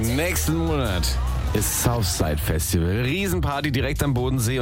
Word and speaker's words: Southside: Wie spricht man dieses Festival nochmal aus? – Southside. – Was sagst du? – Southside. – Southside.